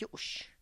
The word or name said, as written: yux